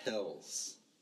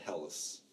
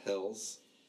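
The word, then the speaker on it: Helles: That right there, it's H-E-L-L-E-S. I had to look it up because I know we're going to be recording. Mm-hmm. So it's pronounced Hells. But I've heard it said Hellas. – Mm-hmm. – But it's Hells. It's a Hells beer.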